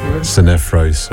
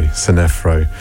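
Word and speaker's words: Sinephro: After the Andre was Nahlas Ninfro. No, that was not how you say it, is it? How do you say it? – Sinefro. – Sinefro.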